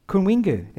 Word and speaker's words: Kunwinjku: Uh, I can't speak Kunwinju. How do you how do you even say it? But I can support CMS and the Pearsons in Gunbalanya. How do you say it? Kunwinjku.